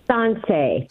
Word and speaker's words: Sansei: Is it Sansei? – Sansei. – Sansei. – Yeah, Sansei, S-A-N-S-E-I.